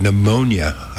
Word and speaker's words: pneumonia: For Alabama, they have pneumonia, I saw. It's on the map here that I'm looking at here. – There are many people that don't realize the P in pneumonia is silent.